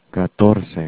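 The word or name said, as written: catorce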